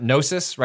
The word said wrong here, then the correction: Gnosia: right? Is that how we're saying the game? – I think the name of this game is Gnosia.